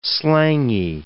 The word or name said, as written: slangy